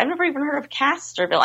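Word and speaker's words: Castroville: But he was talking about the garlic festival, and he said, and Sam, correct me if I say this wrong, there's a Castleville artichoke festival? – I think he said Castorville.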